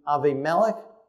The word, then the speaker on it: Abimelech: Both had famines, both went to Abimelech, as it's pronounced in Hebrew.